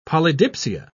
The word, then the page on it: polydipsia: British English